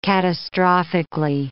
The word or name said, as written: catastrophically